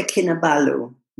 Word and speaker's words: Kinabalu: It's called Mount Kinabalu. Am I pronouncing that right? – Kota Kinabalu, yeah.